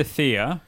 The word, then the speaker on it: Thea: You've got his sister Thea, rhymes with Mia.